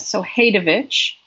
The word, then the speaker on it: Hadewijch: Her name is Hadewij, Hadewijch. – I'm glad I asked. – It's a silent J, Hadewij of Antwerp.